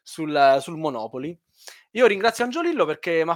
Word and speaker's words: Monopoli: con l'accento sulla seconda O, quindi invece che sulla terza, quindi Monopoli ma senza la Y.